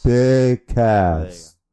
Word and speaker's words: Cass: Oh, sorry, Big Ass? – No. – Big Cass? – Big Cass. Oh, go. I gotta say it right.